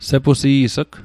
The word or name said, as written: sepusi's'g